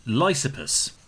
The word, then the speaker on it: Lysippus: And that is the famous slash infamous Greek sculpture Lysippus. Did I pronounce it right this time? – You did, but you said sculpture, not sculptor.